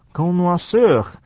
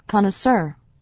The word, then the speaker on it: connoisseur: B 段首先看的是它的第二段啊，第一段话里面说 OK Mr. Coffee Connoisseur，这个字啊原来是来自法文的，是念作 Connoisseur，所以这个翻成英文之后呢念法呢有一些不一样，我们 分别念一下 Connoisseur 或者 Connoisseur。